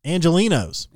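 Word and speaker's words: Angelenos: Quote, we actually found that about 5% of Los Angeles people, how do you say that? Angelinos. – Los Angelinos. – Angelinos. – Yeah.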